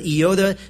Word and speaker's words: iota: In Matthew 5, Jesus himself says, truly I say to you, until heaven and earth pass away, not an iota, that's the correct pronunciation, not an iota, not a dot, will pass from the law until all is accomplished.